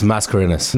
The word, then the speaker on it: Mascarenhas: It's Mascarenhas.